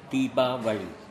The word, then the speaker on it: Deepavali: In our language, mother tongue, is called Tamil, Deepavali.